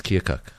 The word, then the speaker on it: Keokuk: Did I say that correctly? Keokuk? – Yes, Keokuk.